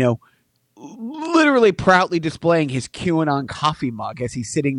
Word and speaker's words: QAnon: Well, no, you're right because, you know, it's – dude, this whole QAnon – am I saying it right? – Yeah.